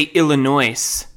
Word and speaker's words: Illinois: Illinois, there's an S. Is it a silent S? Or do I say Illinois?